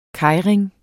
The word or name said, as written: keirin